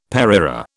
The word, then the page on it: pareira: British English